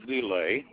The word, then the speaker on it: LIL: Starting with Taitz and ending with Lile, which is pronounced Lil, which has, of course, some jokes about that.